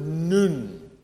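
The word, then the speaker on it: nun (νῦν): But this word now in verse 21 is the Greek word nun. N-U-N. And according to Greek scholars, that's the proper way to pronounce it. Nun.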